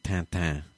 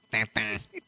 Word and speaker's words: Tintin: It is in America and Canada, but in French, you pronounce it Tintin. – In French, it's Tintin. When I went to Angoulême, Brad, I didn't know that it was pronounced Tintin, and so I'm going, oh boy, this Tintin, boy, that sure is some good stuff.